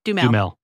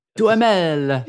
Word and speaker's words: Duhamel: Duhamel. – Let's see what happens. Josh Duhamel? – Duhamel. – Duhamel.